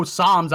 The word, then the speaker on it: Psalms: And I know Psalms. I know the P is silent. Right? You don't say the P in Psalms.